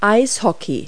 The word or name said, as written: hockey